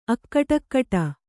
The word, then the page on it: ಅಕಟಕಟಾ: akaṭakaṭā